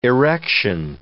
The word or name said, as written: erection